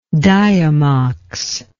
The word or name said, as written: diamox